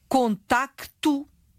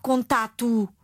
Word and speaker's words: contacto: Oh, Sandra, continuo a ver sistematicamente a palavra contacto escrita sem C. Segundo o novo acordo ortográfico, a consoante que se pronuncia escreve-se sempre, não é? Não há dúvidas sobre isto. – Ora bem. – Portanto, é contacto, não é contato.